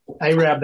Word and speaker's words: Arab: Arab, that's right. How could I forget? – A-R-A-B, pronounced A long A, Rab, not Arab.